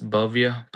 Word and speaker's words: Bhavya: My name is Bhavya Patel, B-H-A-V-Y-A. Last name is Patel, P-A-T-E-L.